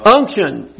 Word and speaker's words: unction: Unction. That's how you say it. – It's like gumption, but unction.